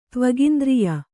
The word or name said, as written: ತ್ವಗಿಂದ್ರಿಯ